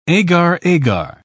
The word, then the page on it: agar-agar: British English